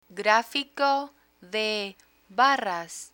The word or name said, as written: grafico de barras